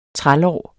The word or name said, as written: trælår